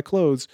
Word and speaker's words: clothes: Or, I sure love going to Target to buy my clothes. Most of them pronounce it the C-L-O-S-E way, even though it's not how it's spelled.